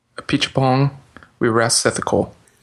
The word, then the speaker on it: Apichatpong Weerasethakul: Apichatpong Weerasethakul is his name.